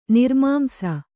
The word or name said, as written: ನಿರ್ಮಾಂಸ